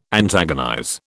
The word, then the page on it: antagonize: British English